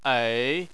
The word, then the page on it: ei: 3